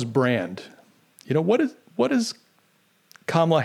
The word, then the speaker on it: Kamala: I think that's how you say her name. It's not Kamala, and it's not Kamala. It's Kamala. Kamala.